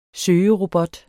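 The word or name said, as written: SØGEROBOT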